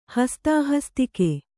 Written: ಹಸ್ತಾಹಸ್ತಿಕೆ

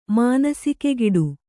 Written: ಮಾನಸಿಕೆಗಿಡು

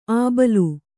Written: ಅಬಳ

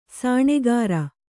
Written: ಸೋನಗಾರ

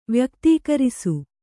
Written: ವ್ಯಕ್ತೀಕರಿಸು

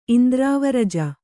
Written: ಇಂದ್ರಾವರಜ

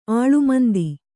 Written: ಆಳ್ಮಂದಿ

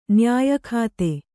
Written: ನಯಯುಕ್ತೆ

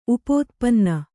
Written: ಉಪೋತ್ಪನ್ನ

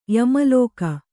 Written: ಯಮಲೋಕ

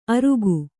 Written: ಅರುಗು

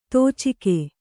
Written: ದುಚ್ಚೌಕ

